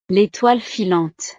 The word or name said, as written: l'étoile filante